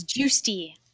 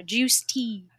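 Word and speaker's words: Giusti: Oh, my last name is Juicy, like orange juice and iced tea. Stick them together, Juice Tea.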